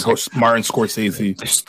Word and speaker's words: Scorsese: And, you know, what is it, Scorsese? I don't know if I'm saying his name right. – Yeah, Martin Scorsese.